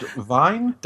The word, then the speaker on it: Wien: I don't speak German, but I'll try dot Vine. – Is that it? So dot Wien, which of course is the authentic German pronunciation and spelling for Vienna.